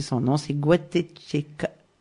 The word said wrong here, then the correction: Gatacheca: je crois. – C'est Patricia Gatacheca. – Gatacheca, voilà.